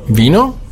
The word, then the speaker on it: vino: Dann haben wir das V, wird ausgesprochen wie ein weiches B, vino.